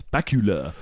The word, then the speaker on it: Bakula: So, I think that's how you say it. It's Bakula. Scott Bakula.